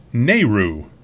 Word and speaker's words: NAIRU: which we really do call the NAIRU, pronounced Nehru.